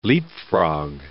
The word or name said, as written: leapfrog